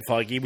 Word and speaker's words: Feige: Well, I say Kevin Feige, which is how I've heard it pronounced by others.